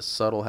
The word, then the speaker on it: subtle: Yeah, when I was a kid, I didn't know that subtle had a silent B.